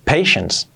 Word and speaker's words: patience: Pero paciencia, como tal, la paciencia, se escribe patience, pero la pronunciación es patience. Parece que es una T, pero no, patience. Es la N y la S ahí, patience, ¿vale? Patience, patience, acabado en S.